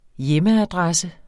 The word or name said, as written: hjemadressen